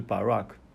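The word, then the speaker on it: Barak (ברך): Barak 这个字母啊，把第一个 Ba，第二个 Rush，第三个 Ka，然后三个字母把它读音连在一起就是 Barak。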